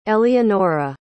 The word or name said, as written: eleonora